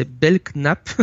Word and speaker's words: Belknap: J'ai toujours dit Bernard, mais c'est Belknap. Ça s'écrit B-E-L-K-N-A-P, c'est imprononçable.